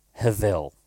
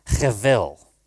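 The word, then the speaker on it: hevel (הבל): the Hebrew word hevel, spelt with a B, pronounced with a V. And I want you to say that word with me with some hearty Yiddish nasal action, hevel.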